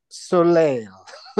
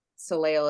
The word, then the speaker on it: Soleil: Plein Soleil. P-L-E-I-N-S-O-L-E-I-L. Plein Soleil. – Oh. – Like Soleil Moon Fry. – Sure. Right. – Is how Soleil is, I assume, spelled and pronounced.